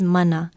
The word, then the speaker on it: mana: Aloha, and our Hawaiian word of the day is mana. Mana, spelled M-A-N-A with no diacritical pronunciation marks, means supernatural or divine power, miraculous power, authority.